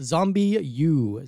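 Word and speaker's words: ZombiU: It is a French game set in London, but it is I believe it's pronounced Zombie U.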